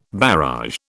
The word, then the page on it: barrage: British English